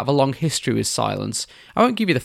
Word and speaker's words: Cylance: They're spelt C-Y-L-A-N-C-E, pronounced Silence.